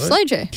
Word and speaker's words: SLOJ: Starting off in the punk scene, he formed bands such as Slow Oj, S-L-O-J. – Yeah. – Pronounce that. Slow J? Slow J. – Yeah, word. Slow J.